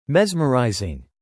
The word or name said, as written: mesmerising